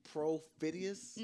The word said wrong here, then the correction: perfidious: No, I ain't say pro. See what I'm saying? – Announce it. – Per. – Per. – Per. Perfidious. Rhymes with hideous. – Per. P-E-R. P-H. I. – He on the song. – D-E-O-U-S.